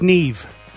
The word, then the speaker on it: Niamh: Neam. – Neve. – No, Neam. N-I-A-M-H. – Yeah, pronounced Neve.